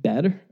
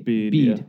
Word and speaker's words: Bede: Saint Bede? I don't know. It's B-E-D. – Bede.